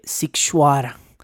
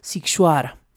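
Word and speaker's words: Sighișoara: Eh, escríbase totalmente distinto a lo que acabo de pronunciar, lo estoy pronunciando fonéticamente, pero se escribe eh, eh para quien lo quiera investigar, Sig, S-I-G-H-I-S con cedilla o ara, pero se pronuncia Sikshuara.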